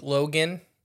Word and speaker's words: Loughan: It's not spelled like Logan. L-O-U-G-H-A-N. – Logan. – I think it is Logan. I watched some of his videos. – Oh, okay. – But it just, it's one of those names that's not pronounced any way it's spelled.